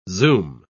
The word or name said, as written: zoom